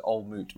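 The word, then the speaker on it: Old Mout: I think it's, yeah, it says on the glass, it says old moot rhymes with fruit.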